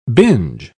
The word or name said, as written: binge